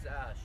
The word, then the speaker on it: Ash: My name is Ash. – Ash? – Yes. – And how do you spell it? – A-S-H.